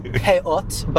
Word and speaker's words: peot: Well, they're called peot, but the Jewish pronunciation is payas. – Got it. Peot? – Yeah, okay. So, I don't want to get into this. But the Ashkenazic Jews, the Jews from Eastern Europe, pronounce this one letter, taf, as a tz, as an S. – Okay. – So they say peot is the word, but they say payas.